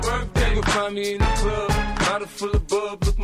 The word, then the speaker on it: In the Club: Dice, ponla de 50 Cent, In the Cloud. – Ubica In the Club, sí, claro. – Ah, In the Club, perdón, sí, es pronunciación sí.